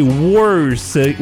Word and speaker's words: worse: Et ça continue comme ça, mais donc corpse, C-O-R-P-S-E, core, C-O-R-P-S, on prononce pas le P, horse et worse, où le O est un E.